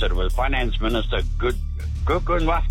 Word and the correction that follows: Enoch Godongwana: If we gave points for pronunciation, you'd be nowhere. Are we in a forgiving mood? I think we're probably on balance going to have to give it to you. It is Enoch Godongwana.